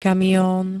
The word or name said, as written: kamion